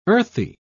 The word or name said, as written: earthy